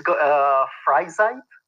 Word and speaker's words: Freizeit: Yes, it's Freizeit.